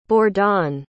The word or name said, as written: bordan